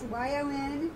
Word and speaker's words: Yonduur: You want to move them over yonder, but it's spelled a little different. So it's Yonduur, and it's an e-commerce marketplace specifically for closeouts and overstocks and excess inventory.